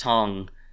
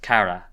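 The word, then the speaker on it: Kara (唐): And in that context, it's not pronounced Tong in Japanese. It's the same, you write the same character, but it's pronounced Kara.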